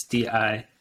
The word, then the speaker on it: Dijon: called Dijon, I think is how you pronounce it. It's Dijon. And the music makes like the mustard.